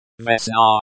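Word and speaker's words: Vesna (весна): Vesna, with the stress on the second syllable.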